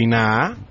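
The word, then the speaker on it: INAH: Pero ellos dicen que es el INAA, la última A más larga para no pronunciar la H.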